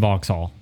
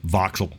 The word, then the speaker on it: Vauxhall: and Vauxhall. Or Vauxhall. – Right. Vauxhall.